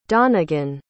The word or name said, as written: donegan